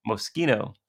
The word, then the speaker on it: Moschino: It was a red Moschino. Am I pronouncing that right? – Moschino.